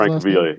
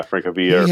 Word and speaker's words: Francavilla: And Francesco Francavilla, I believe is how you pronounce it. – Francavilla, Francavilla.